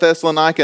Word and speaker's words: Thessalonica: But I've always called it Thessalonica. And then when I looked it up, that's how it was pronounced, how it's supposed to be pronounced.